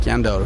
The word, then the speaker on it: SCANDAL: Scandal, but it's pronounced Scandal. – Okay. – They're a Japanese band.